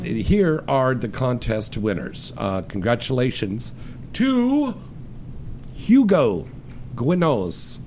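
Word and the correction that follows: Quiñones: Gwynos? I think that's correct pronunciation. – Quinones.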